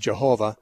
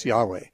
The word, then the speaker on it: Yahweh: The Jehovah's use it, the word Jehovah. Modern scholars would say it's better pronounced Yahweh.